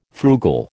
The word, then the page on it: frugal: American English